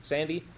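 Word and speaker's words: Sande: As Ken, is it Sand or Sandy? Does anybody know how to pronounce that? – Sandy.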